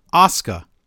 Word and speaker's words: Asuka: Like, for example, Asuka. You want to say Asuka, because that's the proper way to say it. Say it faster.